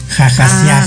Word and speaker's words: Hahasiah: H-A-H-A-S-I-A-H. Pero como es hebreo, la H suena como J, entonces jajasiaj.